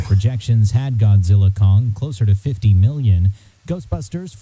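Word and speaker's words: Godzilla x Kong: At the multiplexes, Godzilla Kong, the new empire. Yes, the X is silent.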